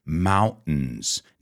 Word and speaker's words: mountains: You know, in the United States, we say mountains, fountains, certain. We have that glottal stop, but you can also say mountain, fountain, certain. Practice, but don't say mountain.